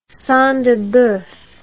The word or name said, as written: sang de boeuf